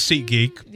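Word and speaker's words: SeatGeek: The ticket seller Seat Greek is also set to give customers the option of seeing all-in upfront prices. – That was Seat Geek, but yeah. Close enough.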